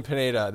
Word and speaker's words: Pineda: Ellsbury, Headley, or Pinata? – You couldn't pronounce that town in England, could you? – No, I didn't. I definitely stayed away from it. – Leicester? Anyway, Ellsbury, Headley, and Pineda.